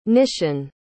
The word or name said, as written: nishan